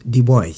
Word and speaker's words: Du Bois: Son nom s'écrit Du Bois, ça se prononce Du Bois.